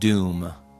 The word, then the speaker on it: Dum: It's spelled D-U-M. It's somewhere between dumb and doom. Doom.